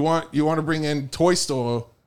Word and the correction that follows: Tolstoy: Tol- Tolstoy.